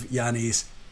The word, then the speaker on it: Giannis: If I was the owner of Milwaukee, I would give Giannis everything.